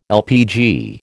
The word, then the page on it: LPG: American English